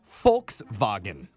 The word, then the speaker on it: Volkswagen: No, not Volks, because the V is pronounced with an F. So it's Volkswagen.